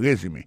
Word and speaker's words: resume: I see from your resume. Accent tombé sur le premier syllabe là.